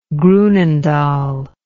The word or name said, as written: groenendael